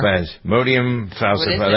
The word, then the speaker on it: Plasmodium falciparum: And okay, how do we say this? Plasmodium falciparum. How's that? Pretty good? – Sure. – Plasmodium falciparum.